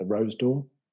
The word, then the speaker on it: Rose d'Or: This is the gold, I'm going to say it right, the Rose d'Or.